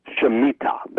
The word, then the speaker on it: Shemitah: But the way it's spelled is S-H-E-M, Shem, and then I-T-A-H, Shemitah.